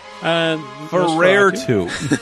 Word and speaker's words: Nosferatu: How do you pronounce Nosferatu? – Ferrer-tu.